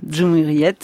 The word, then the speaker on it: Cumhuriyet: Je ne sais pas si je le prononce. – Cumhuriyet, voilà, qui est un très grand quotidien turc, quotidien de référence, extrêmement critique à l'égard du pouvoir.